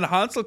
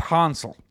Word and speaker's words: Hansel: It's Hansel Robles. – I'm going with Hansel this year. – Yeah. – Soft A.